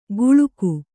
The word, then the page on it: ಗುಳುಕು: guḷuku